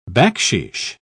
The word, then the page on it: bakshish: British English